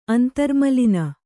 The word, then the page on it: ಅಂತರ್ಮಲಿನೆ: antarmaline